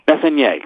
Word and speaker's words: Messonnier: And you pronounced it Messonnier. – Messonnier, correct.